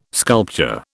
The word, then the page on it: sculpture: British English